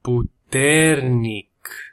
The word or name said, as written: puternic